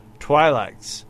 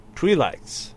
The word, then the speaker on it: Twi'lek: He used the example of a Twi'lek saying people call them Twi'leks, short I sound. Twi'leks, Short I sound.